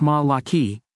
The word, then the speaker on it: Malachy: His name was Saint Malachy, pronounced Ma-la-ki, who made prophecies or predictions, purportedly written in 1139 AD, CE for some, concerning the future popes.